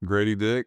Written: Gradey Dick

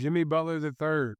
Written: Jimmy Butler III

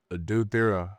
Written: Adou Thiero

